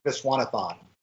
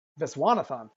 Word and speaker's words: Viswanathan: And Geraldine, I can never pronounce her name properly. – Geraldine Viswanathan. – Viswanathan, thank you.